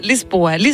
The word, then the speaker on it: Lisboa: Hvordan udtaler man overhovedet det? Lisbon? – Lisboa, ja. – Okay, man siger Lisboa.